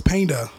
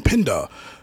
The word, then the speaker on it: Penda: and they would say his name wrong, cause his name was Painda, you know, mm-hmm. Painda, P-E-N-D-A, and they kept saying Pinda.